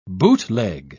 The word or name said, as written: bootleg